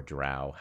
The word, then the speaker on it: drow: So he's a mountain dwarf, hill dwarf, and you know, they were attacked by random sort of drow or drow. How do you say it? – Drow. I would have said drow. – I say drow. – You both say drow.